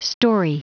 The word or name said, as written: storey